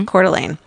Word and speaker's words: Coeur d'Alene: but then they're back in Coeur d'Alene, is that how you say it? – Mm-hmm. – Coeur d'Alene.